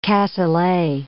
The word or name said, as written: cassoulet